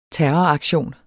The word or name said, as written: terroraktion